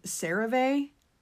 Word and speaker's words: CeraVe: And to be honest, I realized it's pronounced CeraVe instead of CeraVe. I don't know. I think I was trying to be too fancy, but it is pronounced CeraVe.